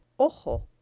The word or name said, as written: ojo